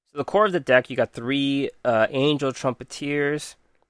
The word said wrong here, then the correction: Trumpeter: Trumpeter, I've been saying it wrong the whole time.